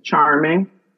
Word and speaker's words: Charmeng: My name is spelled C-H-A-R-M-E-N-G, same pronunciation, Charming, just an E instead of an I.